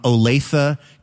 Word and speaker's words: Olathe: The best news that I heard all week comes to us from Olathe, Kansas. And I can tell you, Elena, it is pronounced Olathe, Kansas, because I was recently there and I mispronounced it. And I was corrected.